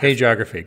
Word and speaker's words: hagiography: How do you pronounce that word? – I always thought it was hagiography. – Hagiography, good.